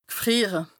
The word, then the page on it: g'frìere: Bas-Rhin; Strasbourg